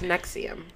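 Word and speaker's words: NXIVM: Oh, it's Nexium.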